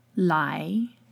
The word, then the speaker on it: lai: When there is the A-I combination in pinyin, it sounds a lot like the long I sound. – Lai.